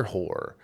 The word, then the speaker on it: Hoar: It was the director Peter Hoare. I'm guessing that's how you say it. It's Hoar, so it's Hoare.